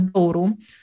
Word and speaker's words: Douro: My ją nazywamy Doliną Douro, bo nazwę rzeki Duero wymawiamy Douro.